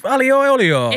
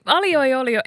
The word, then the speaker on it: aglio e olio: How do you say it? Aglio, meaning garlic, in Italian, e olio. Aglio e olio.